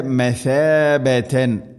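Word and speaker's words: مثابة: ജഅൽന ജഅൽന ബൈത മസാബതൻ. മസാബത്തല്ല സീനല്ല ഥ. ഉച്ചരിക്കുന്ന സമയത്ത് നാവിൻ്റെ അറ്റം മുൻപല്ലുകളുടെ ഇരുനിരകൾക്കിടയിലൂടെ അല്പം പുറത്തോട്ട് നിൽക്കണം നേരിയ തോതിൽ.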